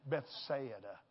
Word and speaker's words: Bethsaida: Bethsaida. Everybody say Bethsaida. I had to learn how to say it. You need to learn too. Bethsaida.